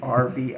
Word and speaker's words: Arbi: And Arby's? – No, it's Arbi. It's like a meats. Middle Eastern name.